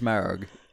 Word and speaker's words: Smaug: It's the glow of Smaug. Or Smaug. I've been saying Smaug. – Yeah, that's right. It's Smaug. Smaug. – Smaug. – You've been saying it perfectly.